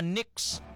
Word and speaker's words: Knicks: So gintawag na siya nga Knicks, silent K.